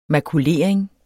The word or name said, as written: makulering